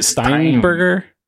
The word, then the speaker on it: Steinberger: Is it Steinberger? – Yeah.